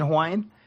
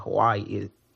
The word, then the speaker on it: Hawaii: A lot of the W's are actually V in Hawaiian. So like Hawaii is not actually Hawaii. It's Hawaii.